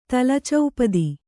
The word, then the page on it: ತಲಚೌಪದಿ: tala caupadi